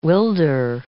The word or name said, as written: wilder